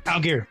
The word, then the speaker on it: Allgeier: Allgeier.